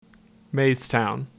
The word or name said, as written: Maystown